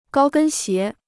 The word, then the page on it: 高跟鞋: gāo gēn xié